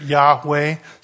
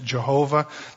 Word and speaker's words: Yahweh: We usually pronounce it Yahweh. Some people pronounce it Jehovah. There are only consonants there. You have to guess what the verbs are, and they've taken the verbs from another title of God and put it in between there, Yehovah. In English, the Y sound comes out J.